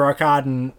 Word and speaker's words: Arkadin: Well, what's interesting is, you know, Mr. Arkadent or Orson Welles. – Yes, that is the correct way to pronounce it.